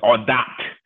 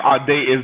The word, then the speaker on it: Audate: You know, it's, my dad says it in the French way. He says Audate, and but yes, Audate is the way that I say it in the United States.